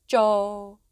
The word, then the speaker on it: Zhou: Shang, da da da da da, Zhou. Shang is spelt S-H-A-N-G and Zhou is Z-H-O-U.